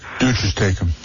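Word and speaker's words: duces tecum: I think it's called Deuces Decalum or something. I used a regular subpoena. – Deuces Tecum. – Yes, that's how you pronounce it.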